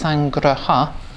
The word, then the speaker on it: sangraha: So it's two words, sangraha and vastu. Sangraha sounds like the word sangha, but it's a different word. It's sangraha, and it apparently means collecting and gathering and uniting.